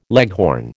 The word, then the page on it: leghorn: American English